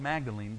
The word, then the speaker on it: Magdalen: It's spelled Magdalene, but it's Magdalen. They say it Magdalen.